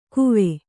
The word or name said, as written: ಕುವೆ